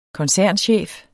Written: koncernchef

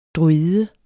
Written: druide

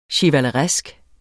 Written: chevaleresk

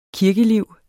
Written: kirkeliv

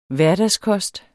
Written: hverdagskost

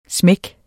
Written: smækken

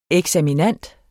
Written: eksaminand

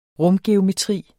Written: rumgeometri

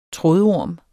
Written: trådorm

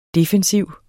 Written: defensiv

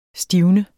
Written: stivne

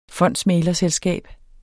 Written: fondsmæglerselskab

